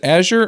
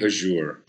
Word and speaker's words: Azure: Is it Azure or Azure? I've heard it both ways. – Yeah, depends on who you ask. It's probably Azure, but I think that's the most proper pronunciation, but the common colloquial is Azure. – So if you have class, it's Azure, and if you're like us, it's Azure.